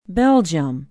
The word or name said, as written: belgium